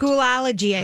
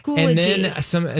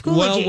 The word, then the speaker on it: Schoology: Schoolology, I thought. – I thought it was Schoolology. – Yeah. But it is Schoology. – And then my producer said it's Schoology. – All right, it's Schoology.